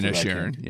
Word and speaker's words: Inisherin: number four is Banshees of, you pronounced the last one. Inisherin. – Yep. There you go.